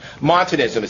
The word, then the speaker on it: Montanism: That is before he went off the deep end and embraced a heresy called Monadism. Montanism is how it's pronounced. Montanism.